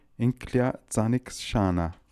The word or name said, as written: Inkliazanikshana